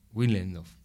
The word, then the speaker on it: Willendorf: Sí, Willendorf.